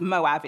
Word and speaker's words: Moab: It's actually pronounced Moab in Hebrew.